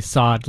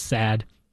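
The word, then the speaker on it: Saad: I say Sod like the stuff in the ground because everyone says sad. – With odd. – It's S-A-A-D.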